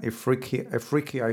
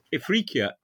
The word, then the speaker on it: Ifriqiya: Let's talk about chapter 3 of the book, where you talk about Sicilian and Ifriqiya, if I'm pronouncing it correctly. – You are. – What were the economic activities like? – Well, Ifriqiya